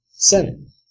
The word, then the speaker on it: seinen: So, the anime I watched this week is a seinen. Seinen. Again, horrible pronunciation. – We're Americans. What can you do? – No, I think it's seinen.